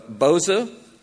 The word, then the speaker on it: Boza: So, welcome to Boza, and I understand from Robert that I am pronouncing it right, correct? Okay, almost like the clown.